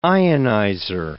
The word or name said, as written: ionizer